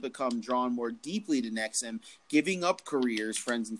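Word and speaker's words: NXIVM: It's like since the 90s, an estimated 16,000 people have enrolled in courses offered by Nexum, pronounced Nexum, which it says are designed to bring about greater self-fulfillment by eliminating psychological and emotional barriers.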